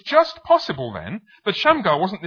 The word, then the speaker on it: Shamgar: The name Shamgar is, well, it's got two syllables in English. It has two syllables in Hebrew, and each syllable sounds a little bit like something which could be a Hebrew word. The word Sham sounds a bit like Shem, which means, anybody know what Shem means?